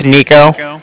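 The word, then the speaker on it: Niko: Like I said, my name's Nico. That's N-I-K-O. And I'm calling from the Bank of Cardiff. And that's spelled C-A-R-D-I.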